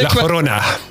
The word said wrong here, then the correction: La Llorona: Ik heb het opgezocht en het is eigenlijk meer zo La Llorona. – Ja, ja, zo het begint met twee L'en, dus dat is de J-klank.